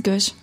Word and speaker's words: Gwiszcz: Dein Name richtig ausgesprochen? – Mhm, Gewisch. Hm. – SC, S, nee, SZCZ.